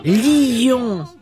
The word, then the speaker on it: lion: Lion. On doit le prononcer en deux syllabes, lion.